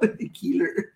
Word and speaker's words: Ekeler: Ekeler. – What? – That's what I'm going with. – So we got Austin Ekeler. – Ekeler. Like, you even mess it up. Ekeler.